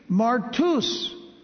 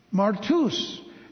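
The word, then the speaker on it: Martus (μάρτυς): Martus. The Y in Greek is, by the way, pronounced like a U. Martus.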